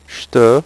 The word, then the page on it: jete: French